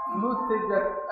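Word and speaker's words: نُتِجَت: وقوله إذا نتجت هذه الكلمة مبنية لفظا للمجهول ومبنية للمسمى من حيث المعنى، تنطق هكذا فيقال نتجت البدنة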